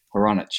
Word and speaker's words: Hronich: Uh, I go by whatever you say, but how it's pronounced would be Ha, your friend Ron, and an itch. So Ha Haronich.